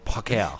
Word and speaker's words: Parker: His real name is Tony Parker. But he pronounced it Parker.